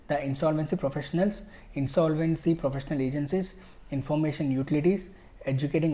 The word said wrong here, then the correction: adjudicating: authorities, sorry, adjudicating authorities.